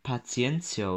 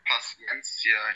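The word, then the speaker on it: Paciência: Ähm, die haben, glaube ich, ein Pas Paciencia, oder wie spricht man denn aus? Von Frankfurt geholt. – Paciencia, ich glaube. Mit S ganz normal.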